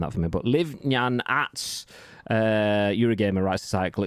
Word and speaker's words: Ngan: Liv, N-G-A-N is the surname. And he said it's pronounced like Nyan, or Nan.